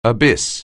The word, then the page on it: abyss: anglais